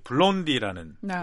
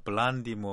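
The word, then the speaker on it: Blondie: 예, 먼저 들려드릴 곡은 이 블론디라는 뭐 미국식으로 발음하면 블란디 뭐 네. 이렇게 될까요? 하여튼 한국에서는 블론디라고 쓰는데 네.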